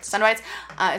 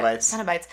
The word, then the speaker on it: Cenobites: Cenobites? – Cenobites. – Is that how you pronounce it? Like... – Cenobites. – The Cenobite... Ceno? I just said it. – Cenobites.